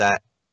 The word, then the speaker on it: Sleipnir: I cannot pronounce this name, but it is Sleipnir, I think is how you pronounce it, which sounds really hilarious. – Is that the... – It's Sleipnir.